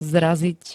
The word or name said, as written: zraziť